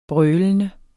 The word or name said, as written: brølende